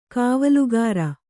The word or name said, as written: ಕಾವಲುಗಾರ